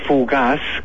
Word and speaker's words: Fugax: Hay un lugar aquí que se llama Fugaz, que ya se pronuncia muy raro porque parece que hablara de fugacidad, pero no se escribe así, sino que se escribe Fugax con X final.